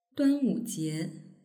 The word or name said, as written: duanwujie